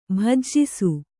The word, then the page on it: ಭುಜಿಸು: Kannada